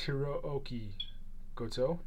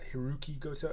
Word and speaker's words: Hirooki Goto: Hiruk, Hiri, yeah, it's Hirooki Goto, not Hiroki Goto. Everyone says Hiroki Goto. It's Hirooki, Hirooki Goto. Hirooki Goto two O's.